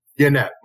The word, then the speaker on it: Yannette: Yannette.